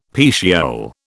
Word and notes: petiole: British English

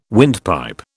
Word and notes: windpipe: British English

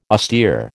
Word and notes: austere: American English

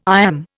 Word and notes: iamb: Indian

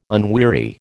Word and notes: unweary: American English

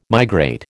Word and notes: migrate: American English

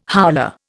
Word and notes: howler: Indian

